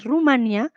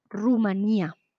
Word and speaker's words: Rumanía: Sigue me dice, me parece curioso que en, la, en Latinoamérica se suele decir Rumania, mientras que en España se pronuncia Rumanía.